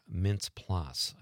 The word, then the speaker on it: Mintz-Plasse: Christopher Mintz-Plasse, I think is this. I don't even know how to pronounce it. – I think that's right. – Is that right? – Yeah.